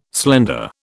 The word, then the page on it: slender: British English